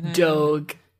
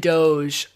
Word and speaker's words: Doge: I just looked it up, and apparently both Doge and Doge are acceptable pronunciations of the meme.